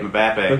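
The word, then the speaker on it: Mbappé: Prospect Mbappe, get it right. Mbappe.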